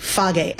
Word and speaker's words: Fage: You got to try the Fage. That's how you say it in Greek. Fage. F-A-G-E. – Is that F-A-G-E? – Yes.